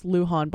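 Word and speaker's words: Lujon: I searched it up, the pronunciation, and I believe it's Luhan by Henry Mancini.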